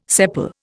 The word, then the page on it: sepal: Indian